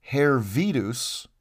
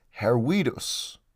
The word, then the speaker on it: Hervidus: But in Latin, the letter V isn't pronounced as V. It's pronounced as a W, like W. Therefore, you wouldn't read it as Hervidus, you would pronounce it as Herwidus.